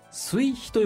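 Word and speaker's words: 水干: でそういうふうに転写が行われて、第3回、これがですね、水に干すと書いて水肥と読むらしいんですが、水肥絵の具による下塗り、ということで、これかなり特殊な絵の具のようですけど、先ほど先生がご紹介くださったそういう作り方をする絵の具のことなんでしょうかね。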